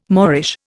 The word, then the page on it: moreish: Indian